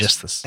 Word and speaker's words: Aegisthus: Aegisthus. Is that how you say it? Aegisthus. Yes. Aegisthus.